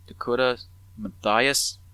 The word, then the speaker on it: Dakota Mathias: They found Dakota Mathias.